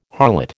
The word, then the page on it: harlot: American English